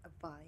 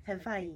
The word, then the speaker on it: Hawaii: Oh, and I learned that the proper way of saying Hawaii is saying the W as a V sound. – Hawaii. – Hawaii.